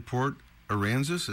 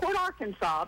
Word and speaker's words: Port Aransas: You are in Port Aransas. Is that how you say it? – You said it right, yes. – Juke Johnson. – Not Port Arkansas, Port Aransas.